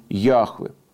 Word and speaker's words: Яхве: Потом наука занималась, изучала, исследовала, и сейчас в библейской традиции, в библейской среде, в научно-библейской среде принято произносить имя Яхве.